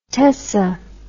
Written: tessa

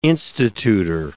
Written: institutor

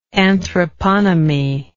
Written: anthroponymy

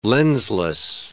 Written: lensless

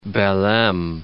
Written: Belem

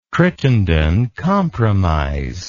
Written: Crittenden Compromise

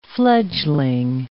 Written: fledgling

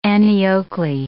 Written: Annie Oakley

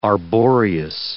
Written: arboreous

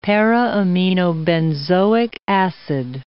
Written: para-aminobenzoic acid

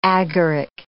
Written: agaric

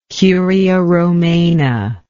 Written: curia romana

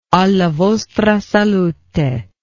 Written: alla vostra salute

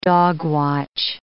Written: dogwatch

